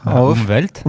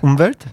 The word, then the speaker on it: Umwelt: Umwelt. – Umwelt. – Oui. – Ok. – Tu l'as très bien prononcé. Hein. – Très bien. – Le W se dit V en allemand.